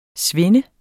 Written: svind

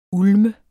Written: ulme

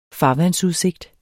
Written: farvandsudsigt

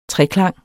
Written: treklang

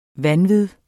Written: vanvid

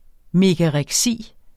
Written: megareksi

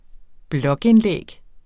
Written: blogindlæg

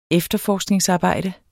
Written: efterforskningsarbejde

